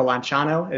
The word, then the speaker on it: Lanciano: But what we see, the first one is Lanciano, or Lanciano is the proper pronunciation,